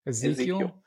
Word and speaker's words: Ezequiel: Did I say that right? – Ezequiel. – That's not how you spell Ezequiel, is it? It's a different way of spelling Ezequiel. – Yeah, Ezequiel got it right, though.